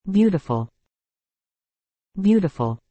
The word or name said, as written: beautiful